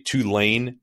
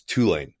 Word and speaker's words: Tulane: By the way, those of you Northerners who say Tulane, no, it's Tulane, emphasis on the first syllable.